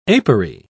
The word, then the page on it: apery: British English